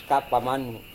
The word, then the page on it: kapamani: Serewen